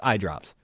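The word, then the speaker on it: iiiDrops: That's how you have to say, that's how it's spelled, but it's I Drops.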